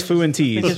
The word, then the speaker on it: Fuentes: Just sounds funny when you say I think it's properly pronounced Fuentes. – Fuentes? – It's Fuentes. I believe that's the proper Hispanic...